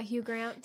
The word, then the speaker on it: Hugh Grant: Yeah, Hugh Grant.